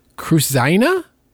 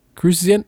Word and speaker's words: Kruszyna: One is F-A-S-E-E-H, Fasi. – Okay. – Fasi. And Joshua, K-R-U-S-Z-Y-N-A. Cruzina? – Yep. – Cruzina? – Nailed it.